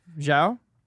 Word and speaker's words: Zhao: Zhao.